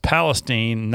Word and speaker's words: Palestine: He says it's pronounced Palestine, not the biblical Palestine, LOL.